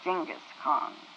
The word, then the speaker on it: Genghis Khan: There was a man once, and we pronounce it Genghis Khan. I think the correct pronunciation is Genghis Khan.